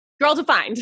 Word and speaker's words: Girl Defined: And I love your passion, you know, with your ministry, with your podcast, Girl Redefined. Did I say that right? – Girl Defined. – Defined. Girl Defined. I was going to say Redefined.